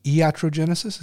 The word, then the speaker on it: iatrogenesis: I think you say iatrogenesis.